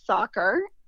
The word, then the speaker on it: Sakhar: The name of the business is Sakhar.